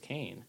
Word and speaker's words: Kane: And we still don't correct people on Kane; it's just Kane.